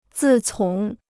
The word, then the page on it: 自从: zì cóng